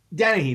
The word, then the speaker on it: Dennehy: Brian Dennehy. – Dennehy, that's how you say it. Dennehy.